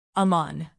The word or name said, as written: amman